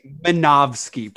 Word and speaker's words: Minovsky: It's just like Zamofsky particles. – Yeah. Whatever those are. – Yeah. – Those are real. – Wait. I think it's Minovsky particles, right? – Wait.